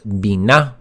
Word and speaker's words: bina: So it's pronounced bina. Say it with me.